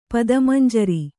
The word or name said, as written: ಪದಮಂಜರಿ